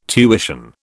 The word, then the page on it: tuition: British English